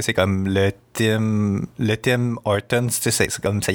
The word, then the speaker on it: Tim Hortons: On dit-tu Tim Hortons ou Tim Hortons? En tout cas, moi, j'ai tendance à prononcer le S, mais en français, on n'est pas supposé les prononcer.